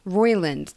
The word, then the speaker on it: Roiland: Is it Roland? Is that how you pronounce it? – What? – Roland? – Roiland.